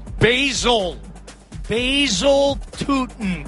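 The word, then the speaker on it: Bhayshul Tuten: It was a 1-yard touchdown run by Shul Tootin. Did I say it right? – Basil Tootin, I believe, is the correct pronunciation, and in a good fantasy play this week, Lodgy.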